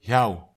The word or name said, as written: hiaw